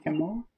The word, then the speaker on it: Kimmel: And then there's people like Jim Carrey, Jimmy Kimmel, or Kimmel. I don't know how to say his name. – Jimmy Kimmel. – Kimmel, yeah. – It's kind of like you're saying kibble, like for dog food.